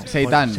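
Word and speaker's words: seitán: Seitan con T. Bueno, Seitan. – Y con acento en la A.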